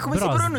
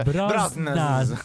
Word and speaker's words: Brosnahan: Come Brosnan. – Si pronuncia? Ogni volta che pronunci il suo cognome sembra che tu non sappia come si pronuncia. – Brosnan.